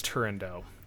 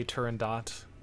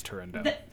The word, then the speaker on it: Turandot: I believe it is Turandot. I have heard some people say Turandot, but the majority of people I've heard pronounce it as Turandot.